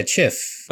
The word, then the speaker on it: handkerchief: How are you pronouncing that? – Handkerchief. Handkerchief? – Yeah, chif. – But it's spelled chief. – Yeah, but that's, you know, that's not how you...